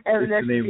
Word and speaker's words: Evanescence: Evanescence? Is that it? – Evanescence, yeah.